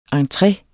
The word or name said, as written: entre